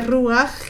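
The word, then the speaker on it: ruaj (רוח): Esta palabra respirar es la misma palabra que se pronuncia ruaj, que es viento, que es viento.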